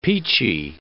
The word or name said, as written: peachy